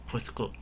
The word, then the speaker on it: pusku: 呃，没有，它是念作 pusku。